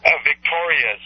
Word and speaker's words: Victorius: Victorious, and that's spelled V-I-C-T-O-R-I-U-S, Alexander.